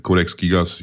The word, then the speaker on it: Codex Gigas: Codex Gigas. Am I saying that right? – Yeah, about right. Codex Gigas. Yeah, it's Latin, so I don't think you can. We can actually butcher it.